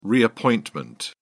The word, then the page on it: reappointment: British English